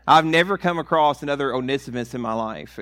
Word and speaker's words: Onesimus: I don't know why I went back to the way I used to pronounce it. It's Onesimus. That's the correct way to pronounce it. And for the longest time, I would pronounce it Onesimus, and I thought that's a weird, you know.